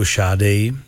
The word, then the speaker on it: Sade: Známe ji jako Šádej, takhle se to správně vyslovuje, jinak někdo říká třeba Sade.